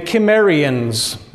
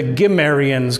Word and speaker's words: Cimmerians: This is the ancient nation of the Cimmerians, or sometimes it's the Gimmerians, because they're that hard G, K. Those are kind of the same sounds, and it's pronounced both ways, okay?